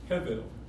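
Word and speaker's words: Hevel (הבל): 虚空的虚空，虚空这个字的原字意思是，原字的发音是 Hevel，不但是虚空，而且是虚空的虚空。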